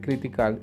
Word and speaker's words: critical: En español, se, en inglés se escribe critical y se pronuncia critical, critical, critical. Una vez más, esa T la pronunciamos como una R.